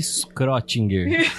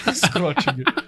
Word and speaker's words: Schrödinger: Na faculdade a gente chamava ele de Schrödinger. – Schrödinger. – Tá válido.